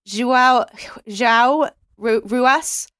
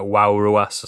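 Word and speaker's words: João Ruas: It's probably pronounced something like Wow Ruas or something like that.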